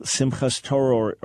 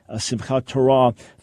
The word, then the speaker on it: Simchat Torah: At the end of this cycle, there's something called Simchas Torah, or modern Hebrew pronunciation, Simchat Torah, the joy of the Torah.